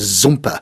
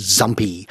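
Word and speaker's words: Zumpe: His name was Zumper. He came from Germany, and he liked in England for his surname to be pronounced Zumpy, Mr. Zumpy, instead of Mr. Zumper.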